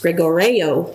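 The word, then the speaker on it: gregoreo (γρηγορέω): The first one is a word, and I put it in the manuscript, but it's a word that's pronounced gregoreo, and it means to be on the alert.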